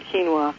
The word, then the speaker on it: quinoa: And I think if you've never tried quinoa, it's spelled nothing like it sounds, Q-U-I-N-O-A.